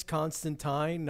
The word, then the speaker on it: Constantine: Constantine. I was corrected recently. – Oh, really? It's Constantine, not Constantine? – The British pronunciation would be Constantine, yeah.